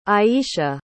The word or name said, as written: ayeisha